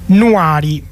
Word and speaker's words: Nuari: E quindi con i tempi delle macchine di Radio Onda Rossa andiamo ad ascoltare invece Nuari. E l'accento è proprio messo sulla A, Nuari.